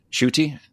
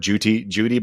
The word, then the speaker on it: Ncuti: Shruti. I've seen enough people say it now. I'm pretty sure that's what it is. I heard Judy before, but I think that was just a mispronunciation of Shruti.